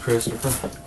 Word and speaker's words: Christopher: It's Christopher. – Christopher. – Yes, sir. – Spell it. – C-H-R-I. – Yep. C-H-R-I. – Yes. – Yep. – T-O-P. – Yep. – H-E-R. – What's your last name, Christopher? – Stacy. – Spell it. – S-T-A-C-E-Y.